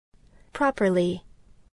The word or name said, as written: properly